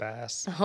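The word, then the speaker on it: Donbas: Donbass.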